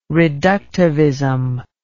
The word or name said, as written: reductivism